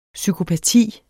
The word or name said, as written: psykopati